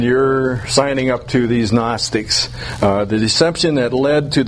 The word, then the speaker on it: Gnostics: It's G-N-O-S-T-I-C-S, and the G is silent. It's pronounced Gnostics. Gnostics.